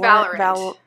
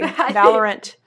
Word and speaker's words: Valorant: Is that how you pronounce it? – I think it's Valorant. – Oh, excuse me. Valorant. – As a professional PC gamer now, I think it's Valorant, okay? – Okay, let me get out of here.